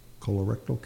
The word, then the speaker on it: colorectal: Can diet affect your probability for colorectal cancer? Did I say that right? – You did.